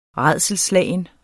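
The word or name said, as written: RÆDSELSSLAGEN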